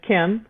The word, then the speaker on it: Kim: So my last name is just Kim, K-I-M. Stepenko is S-T-E-P-E-N-K-O _ Key Wellness all together.